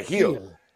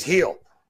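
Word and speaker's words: Gil: And speaking of Gill, so Luis, and I think you don't pronounce it, right? Yeah, Heal. Luis Heal.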